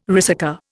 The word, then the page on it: ricercar: Indian